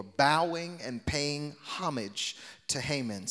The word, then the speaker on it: Haman: Can you say Haman? Uh, in, the orig- in the original text is pronounced Haman, the Agagite, exalting him and setting his position above that of all the officials who were with him.